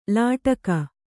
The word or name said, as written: ಲಟಿಕಿ